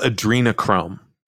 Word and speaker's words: adrenochrome: It's called adrenochrome.